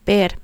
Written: ver

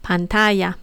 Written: pantalla

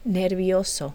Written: nervioso

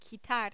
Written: quitar

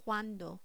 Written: cuando

